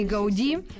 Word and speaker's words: Гауди: 165 э, лет Антони Гауди. Наконец-то мы правильно ставим фамилию, в фамилии ударения.